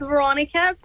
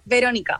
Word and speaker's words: Veronica: It's the American way is Veronica, but the Spanish way is Veronica.